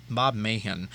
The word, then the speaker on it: Meighan: Not sure how to pronounce that guy's last name, but I think it's Bob Mahan. The G-H is silent.